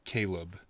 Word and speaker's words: Caleb: I'm Caleb.